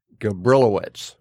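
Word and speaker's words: Gabrilowitsch: was a Russian named Osip Gabrilowitz. – Good job with the pronunciation.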